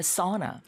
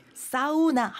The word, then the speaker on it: sauna: 네, 이제 사우나를 어떻게 발음한다고요, 선생님? – Sauna. – Sauna. – 어 발음이죠? – 네, sauna. – Sauna. – 네, 사우나 하면 못 알아듣는다는 거예요. – 맞아요. – Sauna 그래야지 알아듣는다.